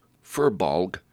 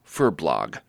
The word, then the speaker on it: furbolg: It's come to my attention that on a number of occasions, I've mispronounced furbolg as furblog, and may have mistakenly identified them as the evolutionary predecessors of Pandarans.